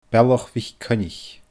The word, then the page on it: Bealach MhicCoinnich: Scottish Gaelic